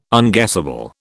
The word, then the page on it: unguessable: British English